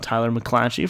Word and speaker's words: Tyler McClatchy: Speaking of, shout out Tyler McClatchy from All Saints Considered.